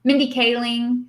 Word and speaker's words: Kaling: Mindy Kaling, who did, I don't think I pronounced her name correctly. I'm sorry. – No, that's right.